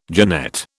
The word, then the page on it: genette: British English